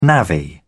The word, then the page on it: navvy: British English